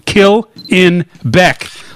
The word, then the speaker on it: Killenbeck: It is pronounced, quote, kill in Beck, like the musician, but I don't want to kill him.